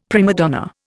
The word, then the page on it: prima donna: Indian